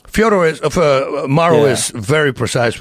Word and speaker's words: Fyodor: We asked Fedor one time, what does your mother call you? He says, Fyodor. I said, we call you Fyodor.